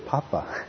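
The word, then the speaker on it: pāpa: paradoxically, it's the word papa. that's how it's pronounced in Pali.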